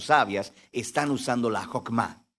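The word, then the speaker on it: Hokmah (חכמה): En hebreo se pronuncia Hokmah y usualmente se traduce al español como sabiduría.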